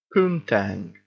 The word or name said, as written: poontang